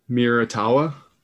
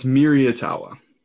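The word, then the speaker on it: Miraitowa: It's Miriatawa because it says its name is based on the Japanese word future. Mira.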